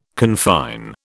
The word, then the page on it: confine: British English